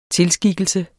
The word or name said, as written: tilskikkelse